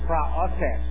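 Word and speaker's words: Praotes (πραότης): It is the Greek word praotes, or some say people say praotes, the praotes. Okay? Praotes, as she said. The right way to say praotes, okay?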